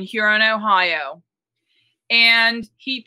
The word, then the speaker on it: Huron: Just don't, say Huron, Ohio. – Yes. The H is silent.